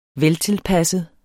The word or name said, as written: veltilpasset